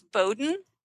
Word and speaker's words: Bowden: It's Z-A-N, so Z as in zebra, A-N. And my last name is Bowden. It's two syllables, bow like what little girls wear in their hair, and den like where a bear lives.